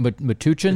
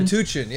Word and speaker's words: Metuchen: Hatch 44 in Metuchen. I don't know how to say it. M-E-T-U-C-H-E-N, New Jersey. – It could have been Metuchen. – Metuchen, yeah, let's say that.